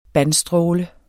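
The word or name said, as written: bandstråle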